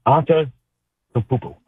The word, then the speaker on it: Antetokounmpo: Obviously, there's big Giannis DeCumpo and Wimby, or how Giannis and DeCumpo, how you say his last name again? How y'all, anybody got it? Anybody got it proper? Say it again. – Giannis Antetokounmpo. I think I said it right.